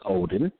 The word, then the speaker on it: Odin: Again, that was O-D-E-N, pronounced Odin.